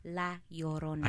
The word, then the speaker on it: La Llorona: La Llorona. – I can't roll my It's a double L, so that's pronounced like a Y.